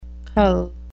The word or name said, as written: cull